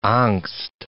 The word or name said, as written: angst